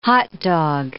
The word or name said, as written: hot dog